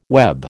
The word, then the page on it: web: American English